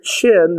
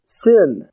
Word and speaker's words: Shin: Sin. But if you put the dot on the right-hand side, it's pronounced Shin.